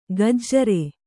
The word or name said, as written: ಗಜ್ಜರೆ